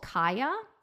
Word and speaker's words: Caya: But dear listeners, there is a one-size-fits-most diaphragm called Kaya. It's spelled C-A-Y-A.